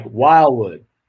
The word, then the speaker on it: Wildwood: You don't pronounce the D really. It's like Wildwood.